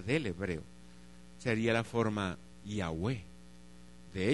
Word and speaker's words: Yahweh: Después lo pasamos cómo se pronunciaba esta forma, y sabemos que se pronuncia como Yahweh.